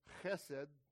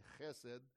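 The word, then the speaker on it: chesed (חסד): This is pronounced chesed. That's all right, sister, and good thing you're sitting in that row, because most people will end up getting my spit on them when I say chesed.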